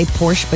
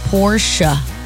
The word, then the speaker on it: Porsche: And for that very famous vehicle, Porsche, a lot of people just say Porsche, but it's Porsche, two syllables. The E is not silent in German, named after Ferdinand Porsche. So it's Porsche, not Porsche, okay?